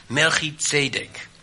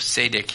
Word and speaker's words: Melchizedek: Melchizedek. Of Zedek, ja, de Z wordt in Nederland niet uitgesproken, maar het is wel degelijk Zedek.